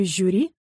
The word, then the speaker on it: жюри: «Парашют» и «брошюра» произносятся твердо, а в слове «жюри» шипящий звук мягкий.